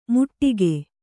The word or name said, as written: ಮುಟ್ಟಿಗೆ